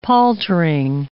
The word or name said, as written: paltering